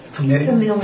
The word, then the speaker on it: familial: But it's definitely light in and of itself, and this familiar relationship familiar. How do you say it? Familial? – Familial. – That's what I meant to say.